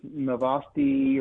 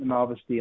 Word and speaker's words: Novosti: It's pronounced Novosti or Novosti. They pronounce it Novosti in the interview.